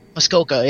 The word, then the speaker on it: Muskoka: I would love to get obviously Half Pints, Flying Monkeys, Muskoka. Did I say that right, by the way? – Muskoka, yeah.